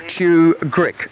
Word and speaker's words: tugrik: Now, he's drawn out some two grick currency, and I'll spell that. It's spelled T-U-G-R-I-K, Chew Grick currency.